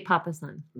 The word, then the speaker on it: Papasan: I think it's Jay Papasan. – Okay, fine. Papasan. It's an American set of authors. So I just tried to use what I think the pronunciation would be in that context. Regardless, P-A-P-A-S-A-N, Papasan or Papasan.